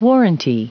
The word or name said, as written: warranty